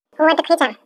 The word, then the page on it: awaatikuhiichaau: Chisasibi